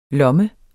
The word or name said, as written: lomme